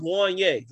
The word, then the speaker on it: Juanyeh: I believe it's pronounced Juan Ye, like from Boyz II Men. But it's spelled J-U-A-N-Y-E-H.